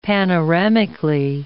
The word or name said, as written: panoramically